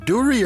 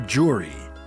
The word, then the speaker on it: Dury: Is it Dury or Jury? It's a D, but I've heard people say it like a J.